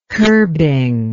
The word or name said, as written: kerbing